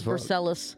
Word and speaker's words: Versailles: Yeah, it's pronounced Versailles.